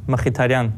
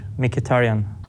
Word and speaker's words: Mkhitaryan: In Armenian you pronounce Mkhitaryan, in English Mkhitaryan.